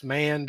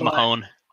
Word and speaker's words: Mahon: I'm going to call him Mahon, even though it's pronounced Mann, because his name is pronounced wrong. It should be Mahon. – I thought it was Mahone.